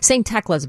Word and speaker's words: St. Tecla: St. Tecla's, by the way. – St. Tecla. – Is that right? Yes. Okay. So sorry to mispronounce that earlier.